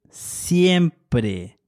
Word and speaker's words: siempre: Let's start with always, which in Spanish is siempre. This is spelled S-I-E-M-P-R-E.